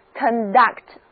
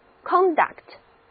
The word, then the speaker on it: conduct: 哎，大家注意了，我一直都是这样来念这个单词的 ,conduct, conduct, 重音是在第二个音节 ,duct 上面。那如果我把重音移到第一个音节，念作 conduct, conduct, 这个时候 C-O-N-D-U-C-T 这个单词就是名词了，意思是举止、行为。